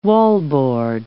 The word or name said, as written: wallboard